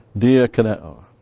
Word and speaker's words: Diakoneo: Diakoneo. This is an interesting... Diakoneo, D-I-A-K-O-N-E, long O.